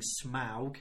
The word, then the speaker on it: Smaug: Another one is obviously the vicious and cunning Smaug, as it's pronounced.